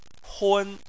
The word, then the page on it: hun: 1